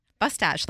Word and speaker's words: Bostich: Bustache, like mustache.